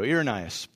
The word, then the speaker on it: Irenaeus: Irenaeus, there we go, Irenaeus.